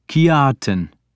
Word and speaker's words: Kjartan: Kjartan, is that how it is pronounced? Kjartan, I corrected him, making the J sound like a Y.